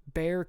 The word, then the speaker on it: Bexar: There's a place in Texas called Humble, and it's actually pronounced humble the same way that Bear County here in San Antonio is pronounced bear and not the way it is spelled, though it is spelled, for all my non-San Antonio friends, B-E-X-A-R. What the heck, dude? That's Bexar. That's not Bear.